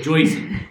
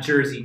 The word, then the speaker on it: Jersey: And before I'm done, one pronunciation I have for you, it's Joyzy. – Joyzy? – Joyzy. Not Jersey, Joyzy.